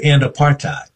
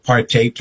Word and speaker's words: apartheid: And I say apartheid because Bishop Desmond Tutu, in one of the times that I saw him, said, I'm going to call it apartheid to rhyme with hate because that's what it was.